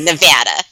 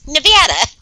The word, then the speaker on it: Nevada: And do you say Nevada or Nevada? – You know damn well I say Nevada. Okay? And I would say Nevada for the rest of my life because that's how you pronounce it. Nevada.